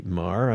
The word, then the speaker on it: Meagher: And it's very appropriate to talk about it today because we have with us Nancy Marr. I'm going to spell her last name because it's not pronounced as it's spelled, M-E-A-G-H-E-R.